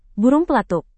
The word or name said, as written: burung pelatuk